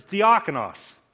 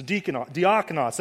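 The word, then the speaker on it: diakonos (διάκονος): It's diakonos. Right? All we did with English is we hack the os off on the end, and then we mispronounce the dia on the front when we just say deacons.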